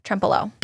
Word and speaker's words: Trempealeau: Did I say that right? – Trempealeau.